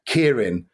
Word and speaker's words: Keirin: And the Anglophone pronunciation now is Keirin, which actually sounds like the Japanese for Kirin, which means giraffe.